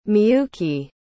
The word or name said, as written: miyuki